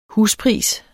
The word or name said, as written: huspris